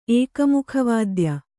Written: ಏಕಮುಖವಾದ್ಯ

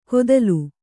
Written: ಕೊದಲು

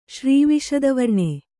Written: ಶ್ರೀವಿಶದವರ್ಣೆ

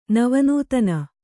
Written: ನವನೂತನ

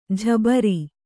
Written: ಜುಬ್ಬರ

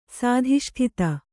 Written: ಸಾಧಿಷ್ಠಿತ

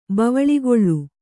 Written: ಬವಳಿಗೊಳ್ಳು

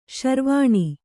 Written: ಶರವಣ

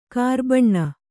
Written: ಕರಬೋನ

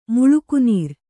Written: ಮುಳುಕುನೀರ್

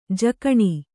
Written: ಜಕಣಿ

